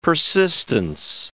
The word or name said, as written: persistence